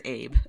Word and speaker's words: Ailbe: Pretty sure it's Ailbe. – Okay. – According to my Googlings. Not okay. Abe, as for some reason Australians pronounce it as Abe. And I'm like, I don't think that's okay.